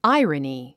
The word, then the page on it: irony: ǽiərəni; アイラニィ